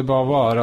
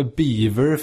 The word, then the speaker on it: Beauvoir: Att de, eh, var det Sartre som liksom de, kallade henne för Beaver för att de liksom för att skoja med att eh, engelsmännen inte kunde uttala det där Beauvoir, att de sa beaver.